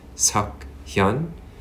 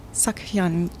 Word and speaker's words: Suk Hyun: My middle name is my Korean given name, which is pronounced Suk Hyun. And my last name is my family name, Yoon. – Hyun. Almost like with an H in front of it a little bit. Hyun. Suk Hyun Yoon.